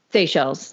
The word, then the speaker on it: Seychelles: So it's pronounced Seychelles. – Seychelles. That's correct.